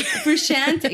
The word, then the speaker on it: Frusciante: Frusciante, yes, Frusciante.